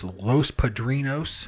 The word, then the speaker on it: Los Padrinos: and so the Devil's Den was, is, I should say, is a place that they refer to as Los Padrinos. Mm-hmm. Am I pronouncing that correctly? Yes.